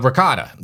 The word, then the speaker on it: ricotta: Yeah, they have the mozzarella, and they have the let me try and pronounce this, Ragotte. Did I say that the right way? – Ragotte. No. Ricotta.